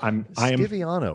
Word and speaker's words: Stiviano: I mean, it's just when, again, presumably the Stivano decide. – Stiviano. Why are you disrespecting her by not pronouncing her name correctly? – I don't know.